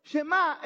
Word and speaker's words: Shemá: Esta palabra se pronuncia Shema y de ahí es donde viene el nombre de la oración. Escucha. Hermanos, Shema es una palabra realmente común en la, en la Biblia.